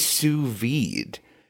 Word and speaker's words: sous vide: The same thing with sous vide. Have you ever sous vided something? – Sous vide. – Sous vide. – D is silent.